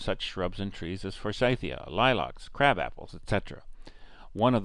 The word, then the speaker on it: Forsythia: It's named after an English horticulturist who brought the first plant from East Asia, and his name was John Forsyth, thus the pronunciation Forsythia.